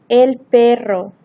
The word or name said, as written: el perro